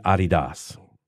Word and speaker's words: Adidas: But everyone else in the world, everyone else in the world, pronounces it Adidas. Do you know why? – I don't know. – Because the guy who started the shoe company's name was Adidas. – Oh, that's how he pronounces it.